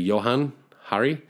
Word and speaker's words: Johann Hari: I think it's pronounced actually Johann Hari.